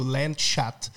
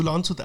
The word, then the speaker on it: Landshut: Ja, du, weißt du, wenn dieser Landshuter hört, wie du Landshut aussprichst, der dürft sofort da und sagt, du, pass auf, das H spricht man nicht mit.